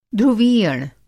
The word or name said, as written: ध्रुवीयण